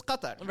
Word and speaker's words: Qatar: If I'm speaking in English, I'll say Qatar. If I'm talking about the correct pronunciation or like the Arabic pronunciation is Qatar.